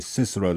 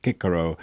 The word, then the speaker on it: Cicero: You know, that they didn't say Cicero, they said Cicero, that the C was always hard.